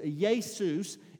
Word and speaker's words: Iesous: it was Iesous in the Greek, not Jesus or but Jesus. It's I E S O U S, but the I is pronounced with the Y.